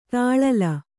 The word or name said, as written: ತಳಿಲು